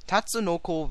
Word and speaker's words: Tatsunoko: Tatsunoko vs.